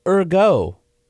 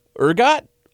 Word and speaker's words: Ehrgott: Got one here from uh, Michael Ergo. I don't know. It spells. It's spelled. – Uh, it's not like ergo. Yeah, it's well. That's how it's spelled. E H R G O T T. Ergot.